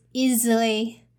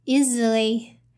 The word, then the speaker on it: easily: And the second one you need to notice is the S sound. The S is voiced sound here instead of voices. Voiced means the pronunciation is easily, easily instead of easily, easily. Okay. Is z instead of s.